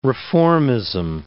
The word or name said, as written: reformism